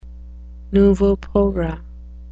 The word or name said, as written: nouveau pauvre